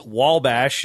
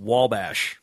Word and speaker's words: Wabash: We did discover last year it's Wabash. It doesn't have an L, but it's pronounced with an L. So when you take the kids out there to Crawfordsville, make sure they know it's Wabash.